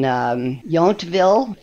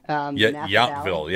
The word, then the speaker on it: Yountville: one in New Orleans and one in Yountville. That's how you pronounce it, Yountville. – Yountville, yeah.